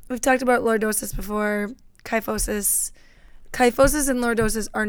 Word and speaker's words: kyphosis: Oh, kyphosis. I've been saying it wrong the whole time. – You only said it once before this. It's kyphosis.